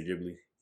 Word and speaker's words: Ghibli: I know I always butcher it. – Studio Ghibli. – Yes, because yep. I always say Ghibli. Yeah. Studio Ghibli.